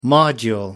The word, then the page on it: module: American English